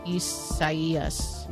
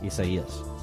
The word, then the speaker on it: Isaias: Well, no, but it's probably the Spanish rendering of it, which in case, and that's if that's the case, then the I is pronounced like an E. – Isaias. – Isaias.